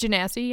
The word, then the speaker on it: genasi: Am I saying that right? Genasi? Genasi? I don't remember. – I think it's genasi, yeah.